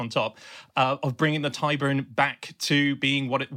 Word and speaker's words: Tyburn: Yes, the Tyburn Angling Society.